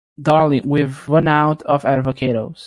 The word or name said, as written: Darling